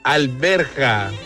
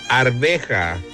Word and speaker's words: arveja: No se dice alberja, se dice arveja.